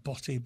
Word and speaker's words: Botti: It's spelled B-O-T-T-I. Not Botti, but Botti.